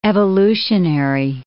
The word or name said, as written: evolutionary